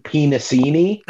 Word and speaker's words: Penisini: The guy's name is John Penisini. That is spelled P-E-N-I-S-I-N-I. Exactly how you'd think it.